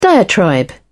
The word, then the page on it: diatribe: British English